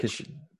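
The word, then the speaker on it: Krkić: I've gone with Krikic Bojan, former Barcelona and Stoke player. Is that you say it? Krikic. Krikic is well, it's spelled K R K I C.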